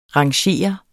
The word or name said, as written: rangere